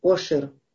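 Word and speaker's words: Ошер (עושר): А одно слово э, Ошер, которое начинается с Сайном, Ошер, а звучит вроде бы одинаково. Э, вроде бы, немножко есть оттенок э, в гортанности э, звука э, ай, но сегодня не, не произносится гортанность у него.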